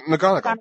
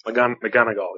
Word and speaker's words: McGonagall: McGonagall, but. – McGonagall, yeah.